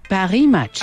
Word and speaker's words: Paris Match: Paris Match.